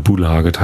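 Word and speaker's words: Systembolaget: Aber schon ein richtiges Bier mit 5% gibt's halt nur im System Bolaget, so heißt der Shop. Oder Bulaget heißt das, wird's glaube ich richtig ausgesprochen. Und ja, da sind wir hingefahren, haben uns eine Flasche Magmyra Brüks Whisky gekauft.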